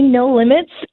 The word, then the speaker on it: No Limbits: Yeah, so the B is silent. We're actually No Limits, and it is an adaptive clothing line for people who struggle with clothing due to a disability.